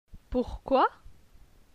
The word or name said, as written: Pourquoi